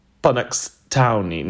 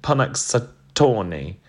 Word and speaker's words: Punxsutawney: Punxsutawney. Now, I know I've said that wrong. Tawny. Punxsutawney.